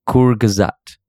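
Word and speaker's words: Kurzgesagt: So Sudin added a pronunciation link to the thing, and it's pronounced Kurgazat. Kurgazat. Mm. And it is it means shortly said.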